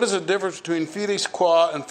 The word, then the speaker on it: qua: Qua. Almost like the island in Hawaii.